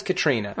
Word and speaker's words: Katarina: It's spelled Katarina, but he says Katrina.